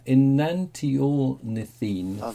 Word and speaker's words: Enantiornithine: the bird belonged to the, here we go, pronunciation challenge, Enantiornithinine. I so almost made that. Enantiornithine family.